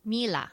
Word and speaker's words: Miele: How do you pronounce it? – Miele. – Miele. – Miele, right? Like Sheila. Miele.